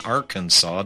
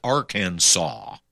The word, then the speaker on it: Arkansas: Here's why we pronounce Kansas and Arkansas differently. Two state names, Arkansas and Kansas, share all but two letters in the exact same order. So why do we pronounce them differently? We can thank the French.